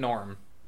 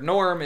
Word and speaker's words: Gnorm: To be fair, Norm is also spelled G-N-O-R-M. – All right, yeah, no, that's still a pun. – The G is silent. So we're in, with your Ford Escort, like Nolan said, maintenance is probably an issue.